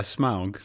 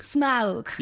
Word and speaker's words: Smaug: Am I pronouncing that right? – You were kind of, yeah, Smaug. – Smaug.